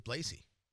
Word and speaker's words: Blasey: I always thought it was Blasey. It's Blasey. That's how I heard it pronounced on TV.